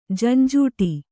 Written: ಝಂಝಾಟ

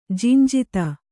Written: ಜಿಂಜಿತ

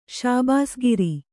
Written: ಶಾಬಾಸ್‍ಗಿರಿ

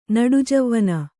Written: ನಡುಜವ್ವನ

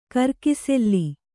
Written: ಕಿರುಕುಸಾಲೆ

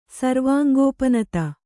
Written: ಸರ್ವಾಂಗೋಪನತ

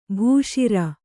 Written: ಭೂಶಿರ

